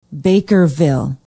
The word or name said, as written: bakerville